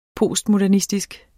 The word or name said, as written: postmodernistisk